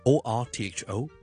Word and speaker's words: orthopedic: orthopedic， author 其实听落去咧系同作家一样，author 一样发音。